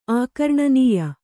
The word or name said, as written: ಆಕರ್ಣನೀಯ